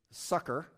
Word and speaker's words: succor: Now, just for your, you know, research, helpfulness, you know, I mean, if you may not use the word succor, that's how you pronounce it, by the way.